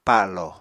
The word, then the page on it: palo: Apatani